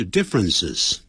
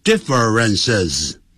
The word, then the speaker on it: differences: difference 变成两个音节 difference 所以呢就变成复数就 differences 就特别记住不要念成 differences 啊，这个念法就不对了